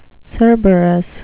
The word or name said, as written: cerberus